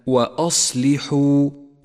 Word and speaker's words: وأصلحوا: أولاً نبدأ بألف التفريق الرمادية في كلمة وأصلحوا وأصلحوا. لاحظ هذه الألف التي تلحق واو الجماعة، لا تلفظ بحال من الأحوال، ولذلك لونت في مصحف التجويد باللون الرمادي إشارة إلى عدم نطقها.